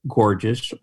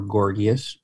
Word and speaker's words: Gorgias: Gorgias or Gorgias, depending upon how you want to pronounce his name,